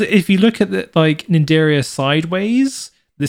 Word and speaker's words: Cnidaria: Cnidaria. – Cnidaria. – The sea is silent. – Silent sea. I don't know why the sea's silent.